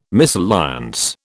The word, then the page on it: misalliance: British English